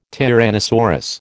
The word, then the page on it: tyrannosaurus: American English